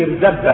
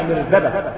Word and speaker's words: مرزبة: في رواية: وبالله اسمع، إن عبد الله بن رواحة رأى ملكا معه مرزبة، مرزبة يعني مفرقة، لا تنطق بالتشديد مرزبة، لا، وإنما مرزبة،